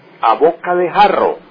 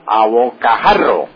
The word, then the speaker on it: a bocajarro: No es a boca de jarro, sino a bocajarro. Esa es la expresión correcta, la que figura en el diccionario y la que se debe utilizar.